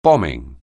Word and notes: bombing: anglais

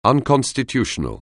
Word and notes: unconstitutional: anglais

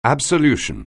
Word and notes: absolution: anglais

